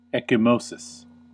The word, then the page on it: ecchymosis: American English